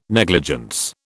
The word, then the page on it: negligence: British English